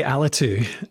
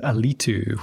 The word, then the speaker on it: Alitu: I say Alitu, but everyone in the US says Alitu, which I like as well. Like, so I'm happy with either.